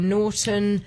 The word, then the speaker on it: Naughton: Joe Norton yeah. is my name. Norton is spelled a very strange way. Mm-hmm. N-A-U-G-H-T-O-N.